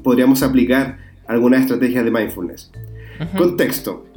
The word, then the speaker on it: mindfulness: el capítulo de hoy en el, bueno, en el capítulo de hoy vamos a hablar de el mindfulness. ¿Lo dije bien o no? Sí, está bien. Mindfulness.